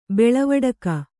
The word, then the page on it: ಬಳವಡಿಕೆ: baḷavaḍike